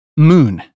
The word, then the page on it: moon: British English